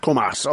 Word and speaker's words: Comas: How close was I on the pronunciation of the last name? Is it Comas? – Comas, okay.